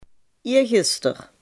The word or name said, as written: eergisteren